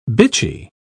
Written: bitchy

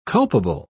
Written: culpable

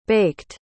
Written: baked

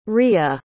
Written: riya

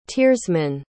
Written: tiersman

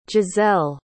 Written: jazzelle

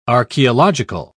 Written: archaeological

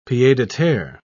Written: pied-a-terre

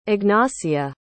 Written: Ignacia

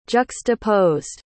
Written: juxtaposed

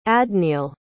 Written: adniel

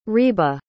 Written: reba

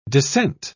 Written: dissent

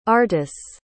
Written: artis

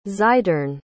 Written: xidorn

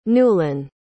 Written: Newlyn

